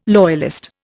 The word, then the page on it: loyalist: Indian